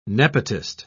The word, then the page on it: nepotist: British English